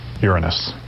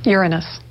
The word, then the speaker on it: Uranus: Strictly speaking, it's pronounced Uranus is the pronunciation. Now, what happened was when the Voyager spacecraft was approaching the seventh planet for its 1986 encounter, the popular pronunciation had become Uranus.